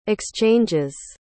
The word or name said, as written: exchanges